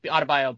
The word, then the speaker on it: Adebayo: Biombo. – Or, sorry, not Biombo. Adebayo, Biombo, because they end in the same syllable, pretty much.